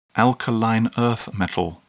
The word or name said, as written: alkaline earth metal